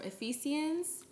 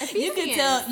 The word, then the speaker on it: Ephesians: Wait, is that not how you pronounce it? – It's Ephesians. Ephesians.